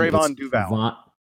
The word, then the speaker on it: Trevon Duval: Duval. Talk about how he pronounces his name. I was paying attention. – Trevon Duval. – Trevon Duval. Duval.